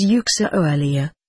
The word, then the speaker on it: Uxiolia: "It is pronounced as Uxiolia, with two equal stresses."